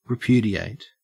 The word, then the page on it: repudiate: Australian English